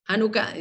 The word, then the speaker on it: Hanukkah: Hanukkah eh, con H se escriben en, hebreo y, y Hanukkah con J en español, pero se pronuncian de igual forma.